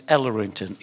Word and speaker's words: Ellerington: To Claude, and my second name is Ellerington, E L L E R I N G T O N.